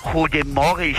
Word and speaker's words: Goedemorgen: No, mentira, se dice Goedemorgen. – ¿Goedemorgen? – Goedemorgen, sí.